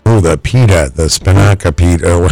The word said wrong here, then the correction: spanakopita: How do you say that? – That's what I was going to say, spinaca or whatever. Spanakopita? – Spanakopita, yes. – Number 6. – That's how you say it.